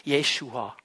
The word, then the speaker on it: Jeshua: So wird der Name Jesu ausgesprochen auf Hebräisch. Jeshua.